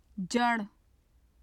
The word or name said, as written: jar